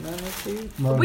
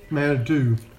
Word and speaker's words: Manitou: How do you say it? Manatee? – We don't care. – Manatee. – Really? Manatee? Like a manatee? – Manitou. – Manitou.